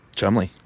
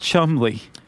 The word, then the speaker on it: Cholmondeley: Yeah, which is, his name is not spelled Chumley. – No. How's it spelled? – Are you ready for this? – Yeah. – Charles, Cholmondeley, pronounced Chumley. – Yeah, and apparently when he met people, he would say, Lieutenant Charles Chumley, Cholmondeley.